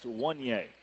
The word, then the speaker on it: Wunya: Spelled W-U-N-Y-A, pronounced Wanye. So one free throw, yay, you know, kind of thing.